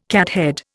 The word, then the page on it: cathead: Indian